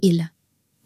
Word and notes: il: French

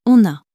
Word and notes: on a: french

